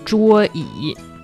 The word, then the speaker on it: 桌椅: Is that Y-I-E? – Yes, Y-I-E. It's the third tone. – Okay. – 桌椅.